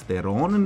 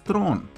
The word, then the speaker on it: Theron: A evo, na primjer, Ksenija upravo gleda da se Charlize Theron u stvari ne izgovara kao Charlize Theron, nego Charlize Tron.